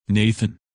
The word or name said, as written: Nathan